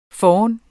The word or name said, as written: foran